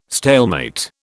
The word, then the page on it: stalemate: British English